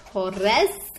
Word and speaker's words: Torres: Torres, if you're going to roll your R's.